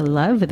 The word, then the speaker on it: Luv: or Facebook at DJ Cherish the Luv, that is spelled L U V, and hashtag using primary food heritage underscore radio, and hashtag RevLove.